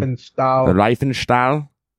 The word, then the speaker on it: Riefenstahl: There was a German filmmaker, Lene Riefenstahl. I think that's how you pronounce her name. – Riefenstahl.